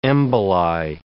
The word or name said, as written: emboli